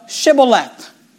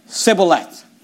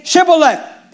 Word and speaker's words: Shibboleth: Then they said unto him, say now, Shibboleth. And he said, Sibboleth. For he could not frame to pronounce it right.